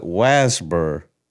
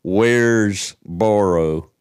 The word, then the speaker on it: Waresboro: We call it Wasbur. Wasbur. But you spell it Waresboro.